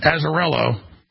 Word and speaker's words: Azzarello: And now we have the manifesto, the man that set himself on fire an hour and a half ago, Max, how do you pronounce his name? Azzarello.